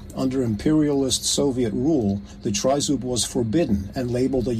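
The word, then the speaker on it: trizub (тризуб): The other symbol is the Ukrainian trident or trizub, pronounced trizub in Ukrainian.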